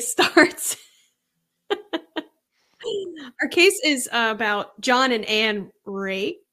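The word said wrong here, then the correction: Racz: I think it's pronounced Rash. It's Hungarian. So for those of you who are listening and want to know why, they're like, why don't they know how to pronounce Rash or whatever, or race? It's spelled R-A-C as in Charles, Z as in zebra.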